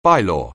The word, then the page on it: bylaw: anglais